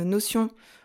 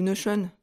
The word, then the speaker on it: Notion: Et d'ailleurs, euh, je vous l'ai pas dit, mais moi j'utilise l'outil euh, Notion ou Notion, selon la prononciation qu'on préfère, qui est un outil numérique.